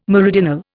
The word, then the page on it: meridional: Indian